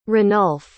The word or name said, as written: ranulf